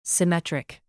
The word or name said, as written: symmetric